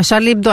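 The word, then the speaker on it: Charlie Hebdo: А Шарли Эбдо они правильно произносили? – Шарли Эбдо. Да, конечно.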